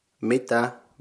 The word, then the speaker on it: mitä: And mitä consists of two syllables, mi and tä. The A with the dots on it is pronounced ä. The first syllable mi is like the first syllable in the word mingle in English. So mi. Tä is something like a tank. So you can use the first syllable of that. Combining them you get mi tä.